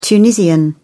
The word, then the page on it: tunisian: British English